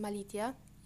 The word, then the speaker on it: Malicia: And finally, let's talk about Malicia, which is actually pronounced Malitia in Latin.